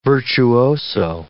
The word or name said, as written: virtuoso